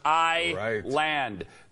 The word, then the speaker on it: Island: And it's not spelled I-S-L-A-N-D. It's I-land.